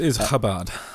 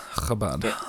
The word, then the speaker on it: Chabad: Yeah, it's Chabad. Chabad. – Chabad, okay. – Like a little bit of a catch in your throat, you know.